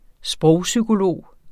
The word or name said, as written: sprogpsykolog